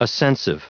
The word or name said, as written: ascensive